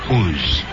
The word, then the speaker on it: Oz: It's spelled exactly the way Oz is spelled. O-Z. But it's pronounced Uz.